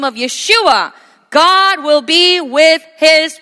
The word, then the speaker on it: Yeshua: Certainly in English it's Jesus, but as we were reminded last night, his name in the original pronunciation is Yeshua,